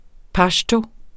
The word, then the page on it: pashto: ˈpɑɕto